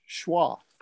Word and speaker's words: schwa: Pronounced schwa.